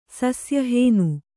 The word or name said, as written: ಸಸ್ಯಹೇನು